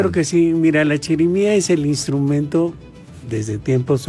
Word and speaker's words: chirimía: la chirimía, ¿verdad? ¿Sí lo dije bien? – Sí, es chirimía, sí.